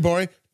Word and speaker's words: Gborie: Speaking of David Bory. David Bory. – Hey. – Cool Guy Joke 77 on Instagram. The yes. G is silent on Twitter.